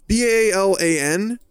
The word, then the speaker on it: Balan: I believe it's pronounced Balan? Is it Balan? It's Balan.